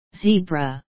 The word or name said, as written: zebra